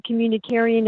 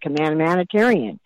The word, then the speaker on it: communitarian: I couldn't pronounce it properly for the first 6 months. I called it command humanitarian.